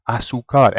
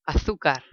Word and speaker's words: azúcar: Because he was putting the emphasis in the completely wrong place. How do you actually pronounce sugar? – Azúcar. – Can you hear where the emphasis is? – Azúcar.